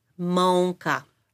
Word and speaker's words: mąka: So flour is in Polish mąka. – Mąka. – Mąka. – Mąka. – Mm-hmm. It is the sound of, it's actually written with A with the little something on the bottom. It's pronounced on.